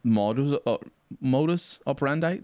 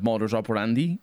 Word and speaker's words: modus operandi: It comes from Latin, sure, but it's an English word, and so it's pronounced modus operandi. That's how it's pronounced, right? Yeah. Instead of modus operandi, which would be correct, more close to what Latin was, but canidae or canidae.